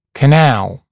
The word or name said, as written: canal